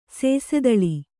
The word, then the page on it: ಸೇಸೆದಳಿ: Kannada